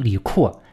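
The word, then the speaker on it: 李适: 反正过了一些天啊，这个唐德宗李阔，这个字写成是时，就是合适的适，但是其实这个音呢，可能应该读作阔。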